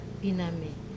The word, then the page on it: paname: wamesa